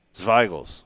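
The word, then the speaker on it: Zweigelt: ZW is pronounced like TSV. Zweigels.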